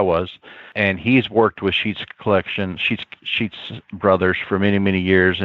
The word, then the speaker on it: Schutz: Sheets is S-C-H-U-T-Z. It looks like shoots, but they pronounce it as Sheets.